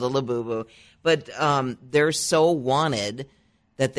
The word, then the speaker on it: Labubu: Have you heard of, and I hope I'm pronouncing this right, the Labubu toy, L-A-B-U-B-U? – Labubu, yeah.